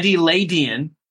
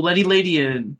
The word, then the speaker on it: Ledian: Secondly, they give a pronunciation for the second one. Ledian. Oh, Ledian.